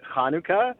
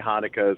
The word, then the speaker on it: Hanukkah: So I pronounce it Hanukkah. But it's certainly just fine to say Hanukkah as well.